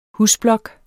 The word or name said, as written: husblok